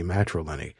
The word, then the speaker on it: matrilineary: matrilineary. Am I saying that correctly? – You are saying it correctly, yes.